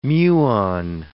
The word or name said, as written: muon